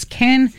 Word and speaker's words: Kean: The author writes, Kane, and her name is K-E-A-N, but it's pronounced Kane.